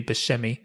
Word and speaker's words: Buscemi: He says Buscemi, I think, but then he went to Sicily and found out it should be Buscemi. He said, I had to go all the way to Sicily to find out I said my name wrong.